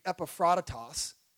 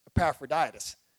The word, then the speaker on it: Epaphroditus: You would be Epaphroditus. But down here in the South, we say it properly. We say Epaphroditus.